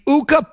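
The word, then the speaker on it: Ukko: Is it going to be Uka Pekka Luukkonen? Uka rhymes with Tuca.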